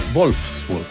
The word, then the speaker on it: Wolfsburg: No, es Wolfsburg, porque la W se pronuncia como V corta, ¿no? O V, ¿no? Se dice V.